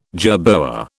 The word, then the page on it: jerboa: British English